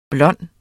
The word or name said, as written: blonde